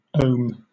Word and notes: ohm: British English

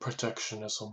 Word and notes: protectionism: American English

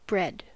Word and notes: bread: American English

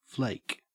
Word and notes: flake: Australian English

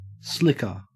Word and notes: slicker: Australian English

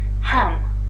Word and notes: ham: British English

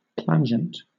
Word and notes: plangent: British English

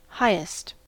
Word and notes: highest: American English

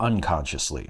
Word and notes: unconsciously: American English

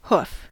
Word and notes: hoof: American English

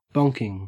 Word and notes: bonking: Australian English

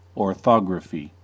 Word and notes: orthography: American English

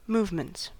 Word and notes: movements: American English

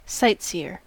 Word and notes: sightseer: American English

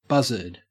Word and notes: buzzard: Australian English

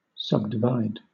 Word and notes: subdivide: British English

